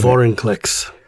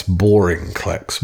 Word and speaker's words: Vorinclex: Vorinclex. Anyways. – You mispronounced that. It's Vorinclex. Vorinclex.